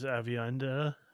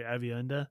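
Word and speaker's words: Aviendha: We got Ayula Smart joining as Avienda. Sorry, Avienda. I don't know why I pronounced that so weird the first time.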